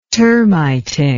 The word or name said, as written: termiting